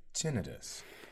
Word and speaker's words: tinnitus: Yeah, because I called it tinnitus, and the doctor's like, "It's actually tinnitus."